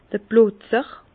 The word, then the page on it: d'r Plotzer: Bas-Rhin; Herrlisheim